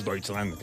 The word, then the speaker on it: Deutschland: Deutschland, ell dit, però és Deutschland, clar.